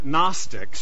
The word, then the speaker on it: Gnostics: So they were called the Gnostics. So you don't always pronounce the G.